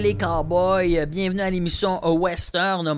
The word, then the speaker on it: Ouesteurne: Western, ça s'écrit comme ça, c'est o u e s t e u r n -E, Western, ça se dit comme ça